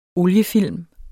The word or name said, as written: oliefilm